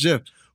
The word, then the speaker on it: GIF: The man who made it says it's pronounced GIF.